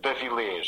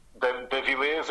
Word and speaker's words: D'Avillez: Yeah, so it's Filipe, and the surname is Davilés. So, or Davilés in Portuguese, Davilés if you prefer, you know, more English.